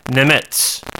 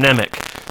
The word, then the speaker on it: Nemec: IMDb, there's somebody who says that his last name is pronounced Nemetz or something like that. And yet, was listening to an interview with him on one of my DVDs, and he announced himself as Corin Nemec. So, given that, I'm going to go with Corin Nemec and trust that he knows how to pronounce his own name.